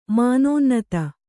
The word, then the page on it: ಮಾನೋನ್ನತ್ತೆ: mānōnnate